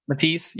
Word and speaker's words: Matisse: Or the XT, or Matassi, is that how you say it? – Matisse.